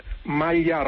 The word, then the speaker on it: maglia: Lo correcto en italiano es decir maglia rosa. – Maglia. – No, el grupo consonántico GL en italiano se pronuncia siempre L.